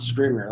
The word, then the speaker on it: Scrivener: Scrivener.